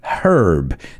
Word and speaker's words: herb: Just be careful because the British, they say herb. They pronounce that H. We don't in English. I mean in real English, in American English.